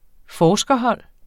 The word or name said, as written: forskerhold